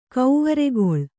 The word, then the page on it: ಕವ್ವರಿಗೊಳ್: kavvarigoḷ